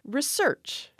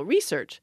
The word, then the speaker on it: research: So I turned to the class, which is almost 60 people, and I said, how many of you have the stress on the first syllable, research?